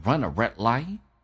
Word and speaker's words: run a red light: Red 的 d 不发音， light 的 t 也不发音，气流阻塞叫 run a red light.